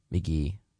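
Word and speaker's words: Mk.gee: And before that, we heard the 2024 single from McGee called Lonely Fight. That's spelled M-K period G-E-E. McGee.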